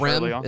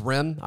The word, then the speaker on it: Rim: And it's pronounced Rim. It's not Rhyme. It's Rim. I've been saying Rhyme.